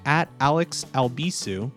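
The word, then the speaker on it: Albisu: If you would like to follow what I do on social media, you can do so by following @AlexAlbisu. My last name is spelled A-L-B as in boy, I-S as in Sam, U.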